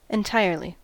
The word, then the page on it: entirely: American English